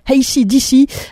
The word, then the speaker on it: AC/DC: Je vais le dire une fois à l'anglaise pour faire plaisir à ceux à qui j'écorche les oreilles chaque semaine. Voici AC/DC avec le titre Shot in the Dark.